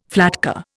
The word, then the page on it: flatcar: Indian